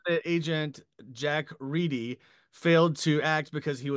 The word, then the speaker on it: Reedy: I called him Jack Reddy. And I'm happy to update it to the correct pronunciation of Jack Reedy. Because only clearly, too recently, I found out, only like about 5-6 years ago, I found out that's the real way you pronounce it.